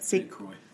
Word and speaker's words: St. Croix: Then on um, August 21st of 2009, Candus's sister, Rose Bly, left her home in St. Croix Falls. I think that's how you say it. St. Croix. Yeah.